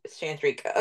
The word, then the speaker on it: Chandrika: Chandrika.